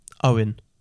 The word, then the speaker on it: Eoin: There's E-O-I-N or E-O-G-H-A-N. Now, how do you think that's pronounced? – Owen. – Yes, it is.